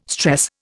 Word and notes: stress: Indian

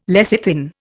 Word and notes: lecithin: Indian

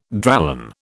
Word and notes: Dralon: British English

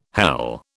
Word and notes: hele: British English

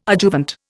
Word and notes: adjuvant: Indian

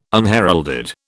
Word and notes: unheralded: British English

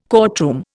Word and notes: courtroom: Indian

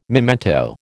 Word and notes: memento: American English

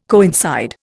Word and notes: coincide: Indian

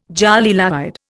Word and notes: jolie laide: Indian

